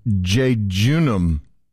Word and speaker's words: jejunum: It's J E J U N U M. Jejunum. – Jejunum. – Jejunum, I got it right.